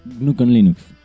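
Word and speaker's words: GNU/Linux: Se pronuncia Gnucon Linux.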